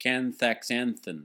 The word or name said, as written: Canthaxanthin